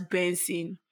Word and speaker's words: BNXN: Pronounced as Benson, formerly known as Buju to your ears.